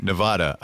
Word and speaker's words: Nevada: was the correct way to pronounce the state that Las Vegas is in, because a lot of politicians would say Nevada. – Wrong. – And that is wrong. – Wrong. It is Nevada. And I had to train myself to say Nevapple so I could pronounce the A right.